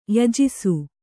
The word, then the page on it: ಯಜಿಸು: yajisu